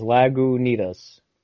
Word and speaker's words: Lagunitas: Isn't there a Lagunitas? – I think that's it's beer. Lagunitas. – Right? Lagunita. – Lagunitas. – Yeah, there's a pronunciation at the top. It says Lagunitas.